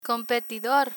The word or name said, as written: competidor